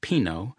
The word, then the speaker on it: Pino: But then I learned that Pino, pronounced Pino, was still alive some 6 decades later, and back in Italy after nearly 30 years in Beverly Hills and Mammoth Lakes, California.